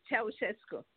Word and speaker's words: Ceaușescu: Nicolae Ceaușescu.